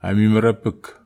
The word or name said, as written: amimera epek